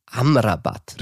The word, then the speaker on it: Amrabat: Ja, und auf der anderen Seite diesem Finale, da stand auch ein ziemlich guter Sechser und das ist Sofian Amrabat.